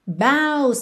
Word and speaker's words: boughs: Baus também é uma palavra que tem uma pronúncia um pouco tricky, B-O-U-G-H-S.